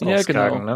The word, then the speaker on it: Skagen: Und dann auf der Ostküste ging es dann weiter, ähm, erstmal mit Skagen, so ist der nördlichste Punkt, oder Skagen, so wie die Dänen das irgendwie aussprechen. Skagen. Ja, aber auch nicht Skagen, so wie wir, sondern irgendwie Skane.